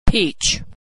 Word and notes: peach: American English

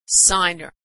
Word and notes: signer: American English

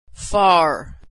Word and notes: far: American English

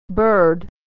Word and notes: bird: American English

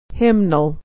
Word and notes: hymnal: hym-nal

